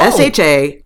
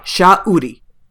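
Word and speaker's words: Sha'uri: But anyway, so her name is Shauri. – Oh. – SHA_URI. Shauri. You will hear them say it. It is two very distinct parts.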